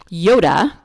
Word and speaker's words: Iota: And look under the story that I tell of a man whose name is Yoda, but it's spelled I-O-T-A, and he is the preacher at the Monterey Shores Church of Christ in Palm Desert.